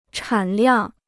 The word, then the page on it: 产量: chǎn liàng